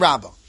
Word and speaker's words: Rabba: So now, according to Rabba, and just to be clear, Rabba over here is with a hey, right? And we pronounce it Rabba.